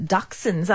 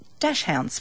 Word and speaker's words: Dachshund: It's not Dachshund. – Yep. It's Dachshund.